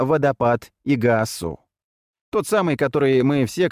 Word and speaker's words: Игуасу: Игуасу, ударение на последний слог, переводится как «большая вода».